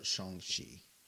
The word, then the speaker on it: Shang-Chi: But I like Shang-Chi a lot, or Shang-Chi, as it's pronounced in the movie.